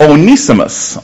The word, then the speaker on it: Onesimus: Now I used to pronounce it Onesimus, and I saw an old commentary and had that long E over the E sound. So I'm going to go with it. Onesimus. I'm going to pronounce him now.